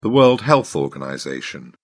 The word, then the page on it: world health organization: British English